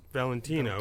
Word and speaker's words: Valentino: And Valentino, which you pronounce Valentino. – Valentino, most people do.